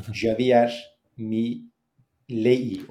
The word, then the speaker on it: Milei: E, Mile e, nasıl okunduğunu ben de yeni öğrendim bu arada. – Yani Javier Milei olarak yazılıyor. Javier Mile olarak okunuyormuş her neyse.